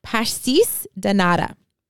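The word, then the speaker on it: pastéis de nata: And I even looked up on Google how to pronounce it, but this was like 2 days ago, so I might not remember exactly, but pastis de nata.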